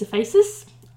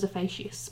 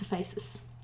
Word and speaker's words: Ephesus: It's Ephesus. I would have thought it was Ephesus, but I'm wrong. It's Ephesus. which is now in the modern town of Selçuk in present-day Turkey.